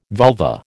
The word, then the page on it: vulva: American English